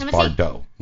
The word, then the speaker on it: Bardot: It's Bardot, like Brigitte Bardot, right? – On the top?